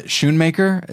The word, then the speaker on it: Schoonmaker: It's Thelma Schoonmaker.